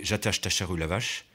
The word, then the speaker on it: Ajatashatru Lavache: Fakir de son état, Ajatashatru Lavache, prononcé Jatashtacharu Lavache, avait décidé de voyager incognito pour sa première venue en Europe.